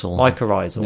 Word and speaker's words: mycorrhizal: Mycorrhizal. – Mycorrhizal.